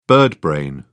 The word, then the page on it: birdbrain: British English